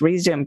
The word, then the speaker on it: Rezoom: And for anyone who's going to look up that book, it's spelled R-E-Z-O-O-M, like Zoom, do it fast, Resume, get back on track fast.